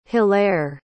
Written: hilaire

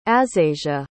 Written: asasia

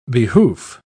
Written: behoof

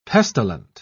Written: pestilent

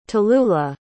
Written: tallulah

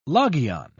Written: logion

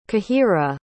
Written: cahira

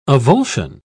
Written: avulsion

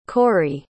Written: kory

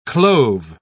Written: clove

